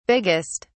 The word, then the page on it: biggest: British English